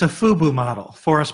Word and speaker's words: FUBU: Wait, how do you say it again? FUBU I know it's a new one. – Fu- Fubu.